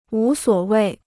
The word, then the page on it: 无所谓: wú suǒ wèi